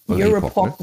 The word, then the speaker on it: Eurepoc: Ihr beide seid an einem Forschungsprojekt beteiligt, das Eurepoc, wie ist die Betonung? – Eurepoc, ne? Würden wir wahrscheinlich sagen. – Eurepoc, okay, ja, vielleicht ein bisschen weniger deutsch ausgesprochen.